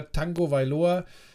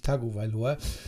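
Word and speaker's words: Tagovailoa: und dass du mit einem, mit einem Tua Tango Vailoa ähm, hawaiianisch ausgesprochen. Das N ist durchs Hawaiianische begründet, deshalb sagt man Tango mhm. – Vailoa und nicht Tago Vailoa.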